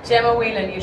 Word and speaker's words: Gemma Whelan: Gemma Whelan.